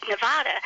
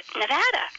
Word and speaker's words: Nevada: I always said Nevada, and it's Nevada. – Nevada. – Yeah. So I was mispronouncing that for a whole lifetime. I'm so happy he fixed me on that one.